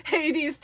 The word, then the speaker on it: Hades: There's, the name is Poseidon, three syllables. Zeus, one syllable. Hades, two syllables.